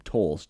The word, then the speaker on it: Toles: I apologize for my ignorance, but I believe it's pronounced just Andrew Tolles, T-O-L-E-S.